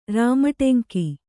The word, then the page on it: ರಾಮಟೆಂಕಿ: Kannada